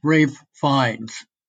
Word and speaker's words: Ralph Fiennes: Both I think his name is pronounced Ralph Fiennes.